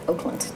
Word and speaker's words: Auckland: Actually, when the New Zealanders pronounce it Oakland.